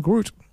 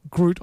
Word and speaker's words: Groote: Yeah, it's spelled because uh, Groot is G R O O T. – Yeah, uh, but this is G R O O T E. But I'll allow it.